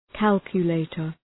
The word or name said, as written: calculator